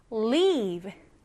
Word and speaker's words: leave: leave, L-E-A-V-E, leave. 조금 길게 발음을 합니다.